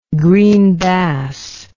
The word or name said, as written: green bass